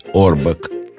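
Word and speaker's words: Auerbach: А фамилию его мы произносим на свой лад, так как произносили в Одессе еврейские фамилии Ауэрбах. Американцы же его фамилию произносят на свой лад – Орбак.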